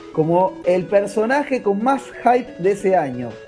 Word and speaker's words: hype: Lo, muy gracioso de este juego es que el juego, previo a su salida, viste que se habla mucho del hype, el, IP, sí. – ¿viste? La, excitación previa a la salida de algo. Se escribe H-Y-P-E, e, hype. Hype.